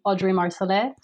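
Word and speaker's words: Audree Marsolais: It's my handle is my name, Audrey Marcellet.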